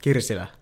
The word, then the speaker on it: Kirsila: KIRSILA.